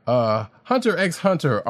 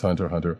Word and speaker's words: Hunter x Hunter: Hunter x Hunter artist remembers late Yu-Gi-Oh! Creator in special tribute. And yes, I say it like that. Come at me. I know it's Hunter Hunter.